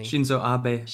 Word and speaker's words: Shinzo Abe: Shinzo Abe.